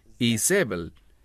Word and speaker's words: Jezabel: Ahora, el término Jezabel, esa es la pronunciación en español. En hebreo es Isabel.